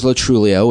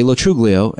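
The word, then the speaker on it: Lo Truglio: The correct pronunciation is Latrulio, which I found, which I started using in my 20s. But my family, most friends say Latruglio, and I think both, because a lot of people, like Birbiglia has a great bit about that, about like, it's not, I don't know if you heard this, it's really funny, but he has a bit about, you know, people who have names like myself not pronouncing the G.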